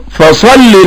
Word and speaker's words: فصلي: صلي ده مبني على حذف حرف العلة اللي هو كان الياء، وكمان مش مرسوم في المصحف، فتقول فصلي لربك. كذلك ما تخطفهاش ان هي ما تنطقهاش، انطق كسرة بس، فتقول فصلي لربك، فصلي لربك، واحرص على الكسر، الكسرات مهمة جدا.